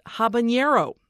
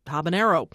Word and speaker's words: habanero: Michael Sulis of Portland, Oregon, wrote to say that we mispronounced habanero. It's actually just habanero. Unlike jalapeno, there is no squiggly line over the N.